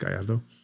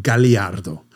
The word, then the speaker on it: Gallardo: By the way, it's pronounced Gallardo, even though Stefan Winkelmann, the CEO of the company, pronounced it Galliardo, which was not allowed because that's an extra syllable.